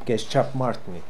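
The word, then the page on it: Keschapmartnee: Russian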